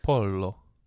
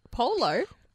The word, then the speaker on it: pollo: It's pollo. – Polo? – Pollo.